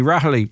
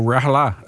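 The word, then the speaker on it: Raghallaigh: Ms. Martin is even facing backlash within the Green Party over her role in the shock resignation of RTE board chair Shuni Rahali, or Rahala, as some pronounce her name.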